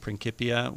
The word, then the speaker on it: Principia: This is from, I've been reading, well, trying to read, Newton's Principia, or I guess some people pronounce it Principia, which is where he explained the theory of gravity.